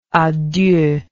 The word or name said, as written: a deux